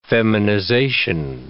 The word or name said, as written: feminization